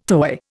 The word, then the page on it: toy: Indian